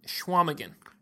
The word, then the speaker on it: Chequamegon: Okay, that's not how you say it. – All right, give me the proper pronunciation. – Schwamigan. – Schwamigan. Okay.